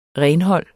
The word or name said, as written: renhold